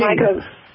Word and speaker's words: Mako: Your client, Mako? Do I say it like that? Mako King? – Mako King. – Mako. Yes.